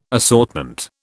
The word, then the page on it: assortment: British English